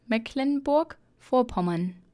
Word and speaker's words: Mecklenburg-Vorpommern: Mecklenburg-Vorpommern.